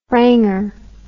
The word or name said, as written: franger